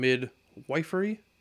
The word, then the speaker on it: midwifery: We'll explore the growing maternity care crisis, like maternal mortality rates, and why expanding access to home birth and midwifery, is that correct? – It's a short I when you add the Y.